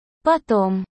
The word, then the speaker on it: потом: Потом. В слове «потом» ударение на первую букву «а».